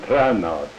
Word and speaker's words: Trana: A-L-B-E-R-T. And Wilhelm, W-I-L-H-E-L-M, Wilhelm. And Trana, T-R-A-N-A.